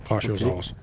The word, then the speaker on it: Brachiosaurus: Und, und äh, eigentlich wenig später kommt ja diese, diese Szene mit dem äh, Brachiosaurus, habe ich es richtig gesagt? – Ja, richtig. Ja. Mhm. Brachiosaurus, okay.